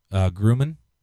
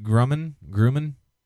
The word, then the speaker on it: Grumman: government payload, which was commissioned by aerospace and defense company Northrop Grumman. Am I pronouncing that right? Grumman? – I believe so.